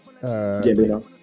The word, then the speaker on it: Gambino: Am I saying his name right? – Gambino.